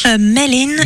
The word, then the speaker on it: Maëlyn: Amélie, une des révélations quand même de ce festival 2026, je trouve, Maëlyn Lewis Trio. Maëlyn, c'est comme ça se prononce ? – Maëlyn Lewis, ouais.